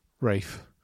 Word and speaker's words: Ralph: Rafe. Sorry. Should spell it differently.